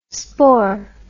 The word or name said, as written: spor